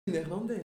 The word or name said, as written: neerlandais